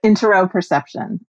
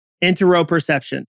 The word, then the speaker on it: interoception: I know for me, one of the things that I initially reacted to was, and I'm going to mispronounce it, is it how do you say it? – Interoception. – Interoception. – Oh, sorry. I said it wrong. – Oh, okay. – Interoception. Interoception. – Like Interoception. – Interoception. Yeah, we got it.